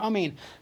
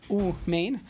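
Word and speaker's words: Amin (אמן): But if a person is not Hasidish and they're pronouncing the wrong way, so is Amin, not Umain. So that would be wrong. Again, if you're Hasidish, that's fine, because that's how you pronounce ah. You pronounce it ooh. So that would be fine. But for everyone else, you should say amen.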